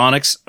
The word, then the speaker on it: Onyx: All right, ladies and gentlemen, this week on The Vinyl Guide, we have a couple of guests here from Onyx Record Pressing. I'm pronouncing that right. Is it? That's Onyx? Right. Onyx, okay. All capitals, O-N-Y-X.